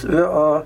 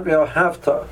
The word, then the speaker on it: ve'ahavta: So I just explained to you that since the word over here means that you should love, the primary trup is going to be on the last syllable, and the secondary trup is going to be under the aleph. So it's ve'ahavta, not ve'ahavta. So the person should be careful to pronounce it like that.